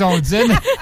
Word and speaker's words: ondines: Odines, je me suis trompé tout à l'heure. Des odines, O-N-D-I-N-E-S, des odines. Il y a aussi des néréides. Je ne prononce bien. N-E-R-E-I-D-E, des néréides.